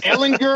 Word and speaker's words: Ehlinger: I was just going to say, like Ehlinger, um, Hard G.